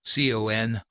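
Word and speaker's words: Con Funk Shun: That's a three-syllable hyphenated spelling. Confunction.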